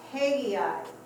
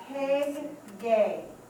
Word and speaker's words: Haggai: It is pronounced Haggai. Even our phonetics, we wouldn't put G-A-I as gay. So it's Haggai is the proper pronunciation.